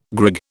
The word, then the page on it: grig: British English